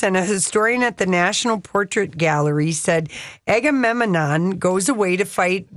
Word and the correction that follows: Agamemnon: Did I say it right, Donnie? – You threw in an extra syllable. That's okay. – Agamemnon.